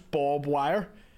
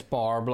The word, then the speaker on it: barbed: I still get daily messages, people going, it's pronounced Bob Wire. – No, it's barbed, like.